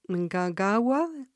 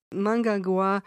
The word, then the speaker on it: Mnangagwa: Mnangagwa. – It's close. – Mnangagwa.